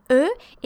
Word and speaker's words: eux: And eux is like de without the D, so it's spelled A. E-U-X.